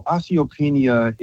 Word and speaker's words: osteopenia: And Dr. Bobby, Miss Jackson wanted to know, can you share a little bit or do you know a light on osteopenia? Is that, am I saying that right? O-S-T-E-O-P-E-N-I-A. Osteopenia? – Yes.